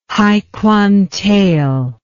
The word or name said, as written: haikwantael